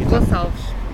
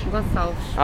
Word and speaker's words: Gonçalves: And is it, I don't want to say your last name. – Gonçalves. – Gonçalves? – Gonçalves.